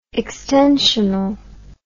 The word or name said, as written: extensionalism